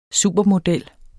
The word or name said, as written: supermodel